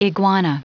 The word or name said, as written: iguana